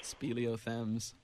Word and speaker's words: speleothems: Speleothems.